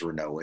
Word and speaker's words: Renault: of Dr. You're saying Renault. I guess it is Renault, isn't it? – That's the French pronunciation.